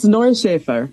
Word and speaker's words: Neuschafer: Yes, it's Neuschafer.